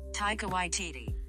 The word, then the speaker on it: Taika Waititi: And even Taiki White, Academy Award winner. I never say his name right. In the first season, Taika Waititi.